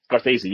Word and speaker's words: Scorsese: Scorsese, yeah, sorry, yeah.